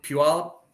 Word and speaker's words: Puyallup: Right below Puyallup.